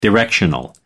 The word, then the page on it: directional: American English